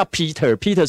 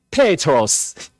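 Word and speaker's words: Petros (Πέτρος): 因为从希腊文来的，那个彼得原来的希腊文， 2000 年前的口音内通用希腊文，不叫 Peter 是英文啊，他的念法叫做 Petros，彼得啦，原汁原味最标准的发音叫 Petros，所以真正的翻译是佩德罗斯啊。彼得，我们所讲的彼得佩德 Petros，那小一般大小的石头呢叫做哦 Petros，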